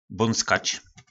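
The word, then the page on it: Bonckac: nářečí Po našimu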